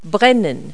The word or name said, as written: burn